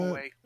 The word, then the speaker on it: Huawei: I think it's pronounced Huawei. Something like that. – Huawei. – Yeah, Huawei. U.S.